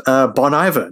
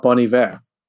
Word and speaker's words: Bon Iver: And it's Bon Iver, not Bon Iver.